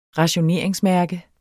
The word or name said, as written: rationeringsmærke